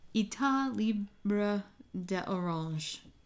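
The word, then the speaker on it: État Libre d'Orange: The name of the house, I believe, is Etat Libre d'Orange. – Yeah. Etat Libre d'Orange. – There we go. – That's spelled E-T-A-T.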